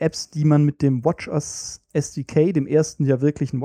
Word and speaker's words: watchOS: Korrektur, die richtige Aussprache ist WatchOS. – WatchOS.